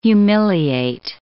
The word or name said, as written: humiliate